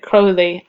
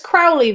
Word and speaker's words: Crowley: I always pronounced it as Crowley, though, but they kept saying Crowley. – I would say Crowley because you think Aleister Crowley. – Yeah, that was kind of the point. But maybe that's like American pronunciation coming in as well.